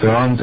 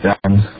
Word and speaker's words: Bernd: My name is Innsbrucken Bernd and German Bernd.